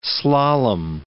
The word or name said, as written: slalom